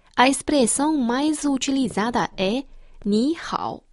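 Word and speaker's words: Ni hao (你好): A pronúncia de ni é parecida com a palavra em inglês ni e hao similar ao hao.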